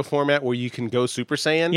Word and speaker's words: Super Saiyan: And Ron, you could tell it's kind of bothering him a little that he keeps mispronouncing it because he loves anime. – Okay. – He's like, it's Super Saiyan,